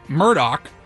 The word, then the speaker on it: Murdaugh: It's Murdoch.